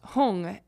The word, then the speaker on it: Hung: And then for like Om Mani Padme Hum, they often pronounce Padme as Peme, like P-M-E. No, P-E-M-E. Peme. And then Hum, which is H-U-M, they sometimes pronounce Hung, H-U-N-G.